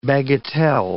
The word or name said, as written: bagatelle